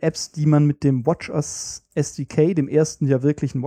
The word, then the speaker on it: watchOS: Die richtige Aussprache ist WatchOS.